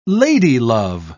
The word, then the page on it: ladylove: British English